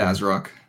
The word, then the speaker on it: ASRock: So this is, I never know how to pronounce their name. Is it ASRock or ASRock? I think I ask you this every time. – We're going with ASRock.